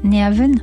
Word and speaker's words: Nyhavn: Je sais que je ne le dis pas correctement. – Oui. – On peut voir de nombreuses maisons aux façades colorées. Alors dites-nous la prononciation correcte de ce quartier mythique. – Nyhavn. Mais vous ne prononcez pas si mal que ça, oui.